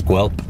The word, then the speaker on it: Guelph: We are on our way to an in-store appearance at the Long & McQuaid here in Guelph, which is actually, a lot of people don't know, properly pronounced as Guelph.